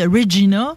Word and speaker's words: Regina: de la prononciation de Regina qui sonne comme Vagina.